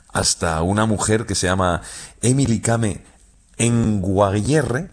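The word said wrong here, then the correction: Kngwarreye: lo he dicho mal. – Emily Kame. – Con Guarreye. – Emily Kame con Guarreye, sí.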